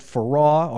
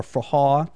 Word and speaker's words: forró: We're going to play about 20 straight minutes of music from Brazil, a style of music called forró or forró, depending on how you pronounce it.